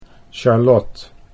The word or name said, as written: Charlot